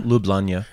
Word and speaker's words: Ljubljana: Ljubljana? – Ljubljana. – It's spelled like L-J-L-U-B. – It's all fucked up. L-J-U-B-L-J-A-N-A, I think.